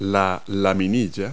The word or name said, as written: gill